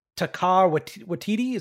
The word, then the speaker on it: Taika Waititi: They just announced, I don't mean to butcher his name, but I believe it's Taika Waititi.